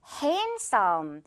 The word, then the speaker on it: handsome: Handsome. 여기에서도 중간에 있는 D는 발음이 되지 않는다는 거 볼 수 있겠죠. Handsome 아니고 그냥 handsome이라고 발음이 나요.